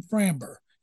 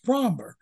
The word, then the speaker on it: Framber: Something that kills me, I'm so tired of people mispronouncing Fromber. All the Fox guys call him Framber. And it's Fromber.